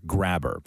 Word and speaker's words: Grabher: His last name is spelled G-R-A-B-H-E-R. And for years, he had a vanity license plate on his car that simply read G-R-A-B-H-E-R, Graber.